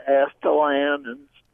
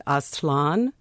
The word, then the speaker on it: Aztlan: It's a word that I think Nahua or Aztec, and that in that language it would be said Aztlan. So that would be one pronunciation.